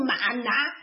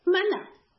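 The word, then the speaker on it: 吗哪: 我不知道为什么全球的华人都要把这两个字读成马拿。其实它标准发音，你看这两个字，它专门是用口字旁写的嘛，就是两个发音的那个词 manna 啊。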